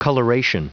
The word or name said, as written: coloration